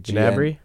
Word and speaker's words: Gnabry: Is that Gnabry guy still good? Am I saying that right? – Which one? – Gnabry or something? It's like Gnabry? – Yeah, Gnabry. Sorry.